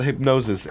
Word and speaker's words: Hipgnosis: Like I said, we do classics, but kind of what is it about Hypnosis? And I should point out that this is spelled HIPGNOSIS, right? – Correct.